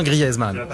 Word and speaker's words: Griezmann: On dit Griezmann, hein. Je le dis bien Griezmann. Griezmann. Comme merguez, soit grillaise.